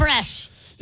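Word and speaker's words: crème fraîche: Creme fraiche. Did I say that correctly? – And chives. Creme fraiche. – Yes, there you go.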